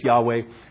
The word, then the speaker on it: Yahweh: Yahweh, YHWH. We don't know exactly how to pronounce it, Yahweh or Jehovah, but most scholars now believe it's Yahweh.